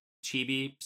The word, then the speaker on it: chibi: Not quite like the chibi style.